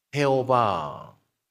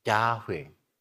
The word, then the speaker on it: Yahweh: Como antes una vez compartí, Jehová, Jehová. Jehová por hebreos así pronuncia Yahweh, Yahweh.